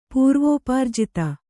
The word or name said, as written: ಪೂರ್ವೋಪಾರ್ಜಿತ